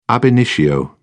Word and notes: ab initio: British English

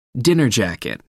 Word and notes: dinner jacket: American English

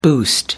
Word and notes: boost: American English

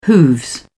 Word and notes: hooves: British English

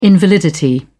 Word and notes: invalidity: British English

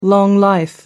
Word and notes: long-life: American English

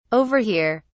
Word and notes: over here: British English

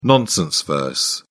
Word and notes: nonsense verse: British English

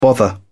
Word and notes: bother: British English

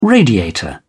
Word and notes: radiator: British English

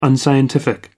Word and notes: unscientific: American English

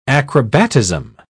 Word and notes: acrobatism: British English

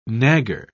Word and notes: nagger: British English

